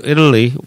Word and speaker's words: Italy: 이탈리 T 발음이 먹히죠. I've been to Italy once. Italy, Italy 이렇게 들릴 겁니다.